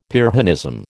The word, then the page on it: Pyrrhonism: American English